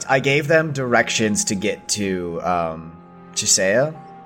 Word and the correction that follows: Chiesa: Am I saying that right, Chisea? I told them. – Chiesa.